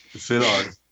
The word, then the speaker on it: Szilard: Szilard.